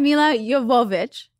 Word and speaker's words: Milla Jovovich: So today I have Milla Jovovich.